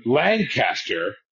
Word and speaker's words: Lancaster: you would be in Lancaster is the same space of time. You said it right. He didn't. He didn't say land. He was.